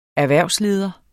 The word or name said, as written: erhvervsleder